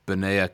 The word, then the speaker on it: Benaiah: So I'm pronouncing it Benaiah. – You pronounce it however you pronounce it. – Yeah, kind of like Isaiah.